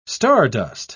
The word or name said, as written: stardust